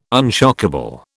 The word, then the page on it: unshockable: British English